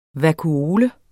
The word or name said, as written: vakuole